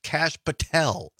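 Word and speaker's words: Kash Patel: Oh, Donna says it's pronounced Cash Patel.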